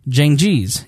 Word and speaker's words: Cengiz: Cengiz. Cengiz.